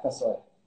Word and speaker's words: kasoy: And it's everybody that wins, I hope you try Thai Orchid's Kasoy. Kasoy? Is that how you pronounce it? – Yeah, Kasoy.